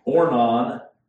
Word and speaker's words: Ornan: Ornan, that's how it's pronounced over there. Araunah is the way it is written here. Just different pronunciations or spellings of the same name.